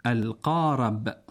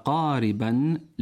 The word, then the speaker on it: القارب: مستمعين الأفاضل، هل تلفظ الراء في كلمة القارب بفتح الراء أي القارب صحيح؟ – كأن نقول ركب قاربا للنزهة بفتح الراء. – الجواب لا، والصواب بكسرها، فنقول ركب قاربا للنزهة بكسر الراء، والقارب عربي صحيح كما في المعاجم اللغوية.